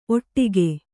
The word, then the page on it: ಓಡಿಕೆ: Kannada